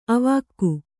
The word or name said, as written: ಅವಾಕ್ಕು